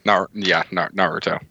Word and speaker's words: Naruto: Naruto? Okay. – Naruto.